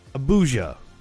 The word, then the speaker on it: Abuja: Did I say it correctly? If it's the Nigerian capital, we should probably look it up. I want to be correct. – Abuja. – I wasn't far off.